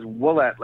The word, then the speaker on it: Willett: It is Willett, like a W.